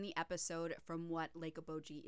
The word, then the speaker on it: Okoboji: In reality, it is O-K-O-B-O-J-I. However, for some reason, in multiple times during the Conduit episode, they spell it O-K-O-B-O-G-G. – Oh. – Like the Bee Gees. Okoboji. – Okoboji.